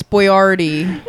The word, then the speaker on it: Boyardee: You're saying it wrong. It's Boyardee.